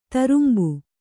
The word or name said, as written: ತರುಂಬು